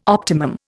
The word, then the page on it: optimum: Indian